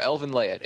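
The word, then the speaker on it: Elfen Lied: Remember, Elvin Laed. It's spelled like Elvin Lied, but it's pronounced Laed.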